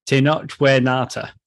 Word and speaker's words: Tenoch Huerta: We should probably give the credit to the actor, Tenoch Huerta.